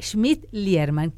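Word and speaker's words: Schmidt-Liermann: Saludo también a la diputada por la Ciudad de Buenos Aires, Cornelia Smith. Siempre lo digo mal, Cornelia Schmidt-Lierman. Coni, es muchísimo más fácil.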